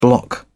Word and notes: bloc: British English

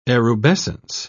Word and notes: erubescence: British English

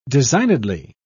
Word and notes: designedly: British English

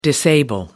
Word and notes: disable: American English

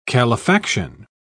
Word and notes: calefaction: British English

